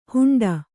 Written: ಹೆಂಡೆ

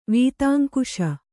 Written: ವೀತಾಂಕುಶ

